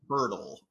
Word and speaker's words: Poeltl: Jakob Poeltl.